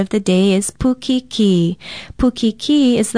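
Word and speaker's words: pukikī: Pukiki is spelled P-U-K-I-K-I with a kahako or stress mark over the last vowel sound.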